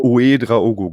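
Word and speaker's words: Ouédraogo: Ich finde da besonders spannend, die Personalie Uedraogo, habe ich das richtig ausgesprochen? – Uedraogo, genau.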